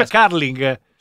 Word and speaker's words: curling: Sì, perché si pronuncia Carling.